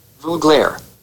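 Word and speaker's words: Veuglaire: Vuglare. – All right, good. – Vuglare. – Vuglare, you heard Volkite it. Volkite Vuglare. – We're never saying it again, but that is the proper pronunciation.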